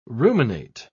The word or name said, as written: ruminate